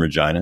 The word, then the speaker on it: Regina: But yeah, so I come from Regina.